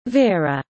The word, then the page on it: vera: British English